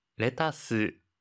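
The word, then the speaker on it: レタス: 하이, 양상추와 양배추는 레타스, 캬베츠와 같이 영어를 일본어식 발음으로 표현하니 주의해주세요.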